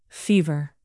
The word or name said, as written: fever